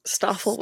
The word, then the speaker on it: Stoffel: This is really random, but I find it very satisfying that Stoffel is Belgian, and like his name is Stoffel, which rhymes with waffle.